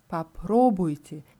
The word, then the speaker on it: попробуйте: Попробуй, попробуйте. Hier ist die Betonung auf der zweiten Silbe.